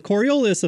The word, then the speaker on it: Coriolis: "While the carniolus, I'm going to call them bees, while the Coriolis effect," I probably butchered that, I'm sorry, "Coriolis, there we go. While the Coriolis